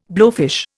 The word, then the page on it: blowfish: Indian